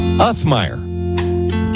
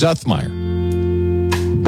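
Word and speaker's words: Uthmeier: Didn't expect that I had it wrong, but I told the Attorney General, I said, when you were chief of staff for Governor DeSantis, I was asking around, how do you say the guy's? And I was led astray. So it's gonna take me a minute to get that Uthmeyer, James Uthmeyer.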